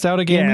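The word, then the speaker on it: Outagamie: Is it pronounced Out of Gamey? – Yeah, it was. – Is that the official local pronunciation? – Yep.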